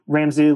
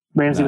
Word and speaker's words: Ramsay: I'm Ramsay Lanier for GitHub. Ramsay with an A, RAMSAY, Lanier.